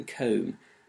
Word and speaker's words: comb: Think of the silent K and GH in the word night, for instance, or the silent G in gnaw, or the silent B in comb.